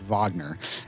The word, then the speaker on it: Wagner: I thought he was Kai Wagner, um, but it's actually pronounced Wagner.